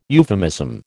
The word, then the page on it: euphemism: American English